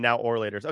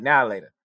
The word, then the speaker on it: Now and Later: When I was a kid, I used to call them now or laters. Okay, so. – See? Oh, we're not going to move on until you say it right. Now later.